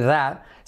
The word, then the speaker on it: that: Fijaos el that, semiclave TH, that, y la T última que casi no se dice.